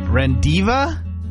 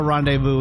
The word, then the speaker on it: rendezvous: R-E-N-D-E-Z-V-O-U-S. R-E-N-D-E-Z-V-O-U-S. – Rendiva?